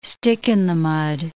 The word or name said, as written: stick-in-the-mud